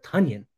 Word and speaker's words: Tonyan: And the first guy I'd like to talk about is Robert Tonyan. Rhymes with Funyuns.